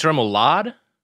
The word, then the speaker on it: remoulade: Which is 6 ounce Angus beef patty, aged white cheddar, sun-dried tomatoes, caramelized onions, bacon, lettuce, remoulade sauce. Am I saying that wrong? – I think it's remoulade.